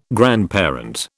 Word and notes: grandparent: British English

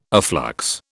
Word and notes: afflux: British English

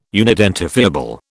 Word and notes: unidentifiable: British English

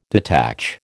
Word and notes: detach: American English

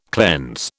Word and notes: cleanse: British English

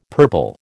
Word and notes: purple: American English

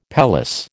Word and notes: pelisse: American English

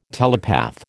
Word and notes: telepath: American English

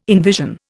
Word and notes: envision: Indian

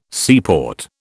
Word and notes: seaport: British English

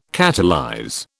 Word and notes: catalyze: British English

